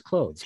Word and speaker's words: clothes: And the director said, hey, Can you pronounce the TH more in clothes? And it was kind of foreign for me. And I felt like I was clothes and it was odd. – So I kind of had to practice clothes, clothes, clothes.